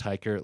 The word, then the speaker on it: Hiker: Brett with two T's, Hiker, like it sounds, even though I learned from some Germans recently that my real last name, I've been pronouncing it wrong the whole time.